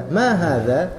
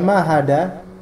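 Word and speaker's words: ma hadza (ما هذا): Tapi biasa aja hadza. Ma hadza. Ya, itu aja. Jangan ma hada, hada salah. Jadi ma hadza, oke.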